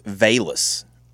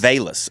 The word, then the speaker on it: Velus: Apparently, it's Velus. – Velus? – Velus, according to the pronunciation guy.